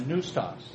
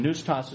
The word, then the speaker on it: pneustos: So the two words are theos and then noustos. And noustos is spelled that way, P-N-E-U-S-T-O-S. Okay? Noustos. Then you put those two together. T-H-E-O, and then noustos.